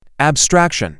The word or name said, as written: abstraction